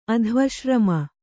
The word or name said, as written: ಅಧ್ವಶ್ರಮ